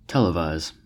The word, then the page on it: televise: American English